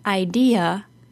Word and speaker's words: idea: In the word idea, the middle syllable is stressed, and the letter A at the end is said as a. Idea.